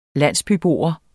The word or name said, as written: landsbybo